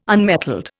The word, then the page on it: unmetalled: Indian